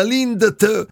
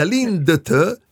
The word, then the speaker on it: Lindt: Ecco, ma intanto si dice lì, come si dice l'azienda? Lindt? – La D non si pronuncia.